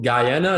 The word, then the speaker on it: Guyana: Then you moved to Guyana, is that how you say it? – Right.